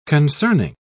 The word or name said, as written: concerning